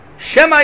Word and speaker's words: Shema: Shema Yisrael. Maybe Yisrael Hashem Okeinu Hashem Echad Chas V'Shalom. So he said, don't overpronounce the Shva. Just but get the Shva.